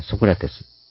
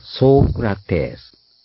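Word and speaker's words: Socrates: ソクラテスという発音である。ソークラテス。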